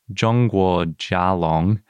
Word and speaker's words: Zhongguo Jialong: but it would be pronounced Zhongguo Jialong, which literally means China. – Right, Zhongguo means China.